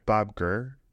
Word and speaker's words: Gurr: Bob Gurr. Am I saying that wrong? – No, you're not saying that wrong.